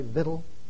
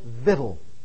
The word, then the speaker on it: victual: Victual? Not victual, vittle. That is the proper pronunciation, not just the East Texas pronunciation.